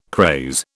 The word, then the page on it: craze: British English